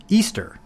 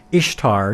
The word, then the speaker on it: Ishtar: This is Ishtar pronounced Easter. No, it very much isn't. That is not how you pronounce Ishtar. You do not pronounce it as Easter.